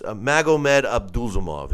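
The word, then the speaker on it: Magomed Abdusalamov: It's Magomed Abdulzamov is how you say his name. It was going to bother me until I said it right.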